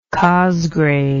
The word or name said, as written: cosgrave